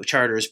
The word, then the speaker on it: Chartres: Chartres being the local pronunciation for those of you touring France right now, you would pronounce Chart.